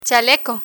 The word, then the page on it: chaleco: spanish